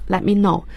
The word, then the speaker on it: let me know: 要注意 let me know，注意他的表达 T 是不发音的， let me know，请让我知道。